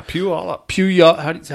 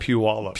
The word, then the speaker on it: Puyallup: Connie from Puyallup, Washington. – Yeah, Puyallup. – How do you say that? – Puyallup. – Puyallup. – Yeah, like pew, but it's Puyallup.